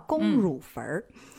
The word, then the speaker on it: 公主坟: 再之后呢，就是静涵说的这个吞音了，公主坟要叫公乳坟儿，王府井呢要念成王五井。